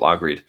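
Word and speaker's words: Lægreid: But I mean, you run through the rest, Roysland, Eckhoff, J2Bo, I always mispronounce his name, and I never knew how it was pronounced until just the other day, but Lagreed.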